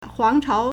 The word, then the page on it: 皇朝: huángcháo